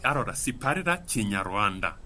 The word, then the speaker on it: Kinyarwanda: Kinyarwanda. – Sì, pronunciate bene questa è la nostra lingua.